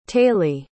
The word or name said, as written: taylee